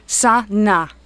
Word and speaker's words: Sana: It's got two syllables, Sana.